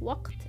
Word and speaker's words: وقت: and there is قاف وقت like this but in Egyptian we pronounce it like ah which is ما عنديش وقت